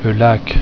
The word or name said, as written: Lac